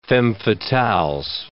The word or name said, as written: femmes fatales